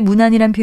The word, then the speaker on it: 문안: 앞서 말씀드린 두 개의 문안이란 표현은 모두 문안 이렇게 짧게 발음하는 반면에 우더른께 안부를 여쭌다는 뜻의 표현은 무난이라고 길게 발음합니다. 세무난과 같이 무난 안에 다른 말을 붙이는 경우에는 짧게 발음하는데요.